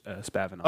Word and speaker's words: Spavinaw: How do you say that? – It's Spavinaw, but they have the statue of him in Commerce.